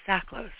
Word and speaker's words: Csaklos: And my guest today, Will, I know I asked you this, is Csaklos, right? – Yes, correct.